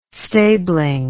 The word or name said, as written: stabling